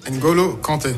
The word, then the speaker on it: N'Golo Kanté: N'Golo Kanté.